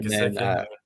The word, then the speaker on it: Gesicki: And I am going with what is Gesicki? Is that how you pronounce that? – Mike Gesicki.